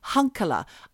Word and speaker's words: Hunkeler: But recently, he was revealed to be named as Ronald Hunkeler. I'll say that again. Oh. Hunkeler. Now, that's spelled H-U-N-K-E-L-E-R.